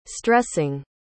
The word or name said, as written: stressing